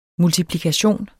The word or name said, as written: multiplikation